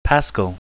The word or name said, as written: paschal